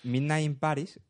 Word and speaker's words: Midnight in Paris: Pero al final es como cuando le- al final es como un poco lo que le dijeron el otro día, que Midnight in Paris, ahora lo he dicho bien, Midnight in Paris se queda en tres, cuatro escenas interesantes.